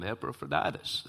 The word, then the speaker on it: Epaphroditus: And I told him, Epaphroditus. That's how I say it.